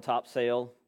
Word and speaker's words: Topsail: I am one of the pastors down at Pillar Church of Topsail, spelled topsail, but pronounced topsail.